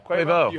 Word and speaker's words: Quavo: Quavo.